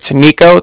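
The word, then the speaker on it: Niko: Okay, so it's Nico, that's N-I-K-O, and then the Bank of Cardiff is spelled C-A-R-D-I-F-F,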